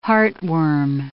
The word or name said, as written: heartworm